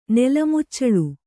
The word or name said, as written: ನೆಲಮುಚ್ಚಳು